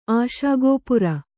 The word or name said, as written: ಆಶಾಗೋಪುರ